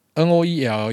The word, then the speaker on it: Noele: 下面一位九九九菜盒子他说，第一次留言，梦公安女友不知道从何时也开始默默成为您的忠实听众，希望您能祝 N O E L E 要怎么念？Noel。 Noel，二月八号生日快乐！我已经可以想象他听到那惊喜的表情了。感谢您的帮忙，祝您与家人新年快乐，龙探吉。然后也祝这个九九九菜盒子和他的女友 Noel 二月八号，